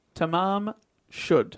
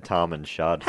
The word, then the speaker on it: Tamam Shud: Is that, well, how do you pronounce it? – Tamam Shud. – It's one of those ones I've seen written down. I said Tom and Shud.